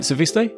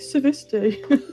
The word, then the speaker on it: Suviste: Is that right, Suviste? – Suviste. I mean, it's one of those where I don't even pronounce it necessarily properly because it's Estonian.